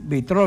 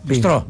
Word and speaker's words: Μπιστρό: Να πούμε την καλημέρα μας στον Παναγιώτη, τον Γίγαντα από το Πιτρό. – Μπιτρό δεν υπάρχει. Μπιτρό. – Ναι. – Μπιστρό λέγεται. – Μπιστρό. Εντάξει, μπιστρό. Δεν τονίζετε πολλές φορές το S.